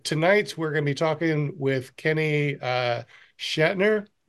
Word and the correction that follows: Schnatter: No, did I say that wrong? – Schnatter. – Schnatter, Schnatter, Schnatter.